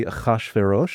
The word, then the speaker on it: Ahasuerus: Ahasuerus. – Okay, what language is that? – So, you know, the Hebrew pronunciation would be Ahasuerus.